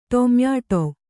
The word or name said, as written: ಟೊಮ್ಯಾಟೊ